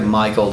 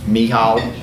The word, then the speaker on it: Michal: it looks like Michael to me, but how would y'all say that? – Michal.